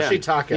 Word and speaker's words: Ashitaka: Ashitaka. – Yeah. Ashitaka.